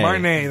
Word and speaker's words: Martinet: Martinet, the French pronunciation.